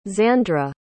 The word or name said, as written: Zandra